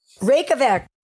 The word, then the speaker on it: Reykjavik: Reykjavik.